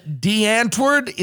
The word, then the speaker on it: Die Antwoord: band DeAntwoord. Is that how you pronounce it? – That's right.